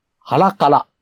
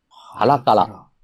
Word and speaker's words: 同胞: じゃあ、4位はですね、 1975 年の映画で、ハラカラ。ハラカラ。同胞と書いてですね、こう。